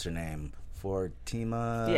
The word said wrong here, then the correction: Fomina: Yeah, Fomina.